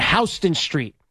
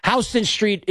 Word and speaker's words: Houston Street: That is Blue Haven West on Houston Street. Now, Houston Street is spelled Houston, by the way. So you're going to be like, well, it's Houston Street.